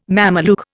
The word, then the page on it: Mameluke: Indian